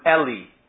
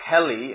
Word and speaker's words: Eli: Even if their name is E-L-I, you call them Eli. It's pronounced differently. So when this is translated into English, very often, sometimes it will be translated H-E-L-I, Heli, and you say, this is Eli, not Heli.